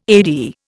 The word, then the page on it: AD: Indian